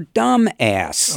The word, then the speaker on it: Dumas: D-U-M-A-S. Of course, the French pronounce it a little bit differently, Dumas. But at 12 years of age, I was convinced that this was the correct pronunciation.